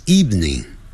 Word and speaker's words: evening: 你今天晚上想要做什么？What would you like to do this afternoon, this evening? Evening 就是晚上 E-V-E-N-I-N-G 下午是 afternoon Evening 这个呢我们很容易读错、哦、 E-V-E-N-I-N-G 我们可能会读成三个音节 Evening